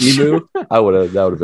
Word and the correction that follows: emo: It's possible my pronunciation was off. I meant emo.